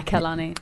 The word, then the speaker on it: Kehlani: So now I'm torn between the B and Kehlani. Is that how you say her name? – Yeah, Kehlani.